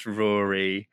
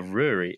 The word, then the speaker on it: Ruari: Um, yeah, so my name is Ruari, or Ruari if you're Irish or Scottish, which my family are.